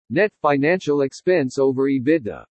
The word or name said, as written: NET FINANCIAL EXPENSE OVER EBITDA